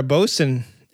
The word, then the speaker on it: boatswain: A boatswain is, or a bosun, if you say it correctly, is a unique brew made in small batches in, I don't know where the fuck it's made.